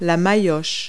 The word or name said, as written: mallet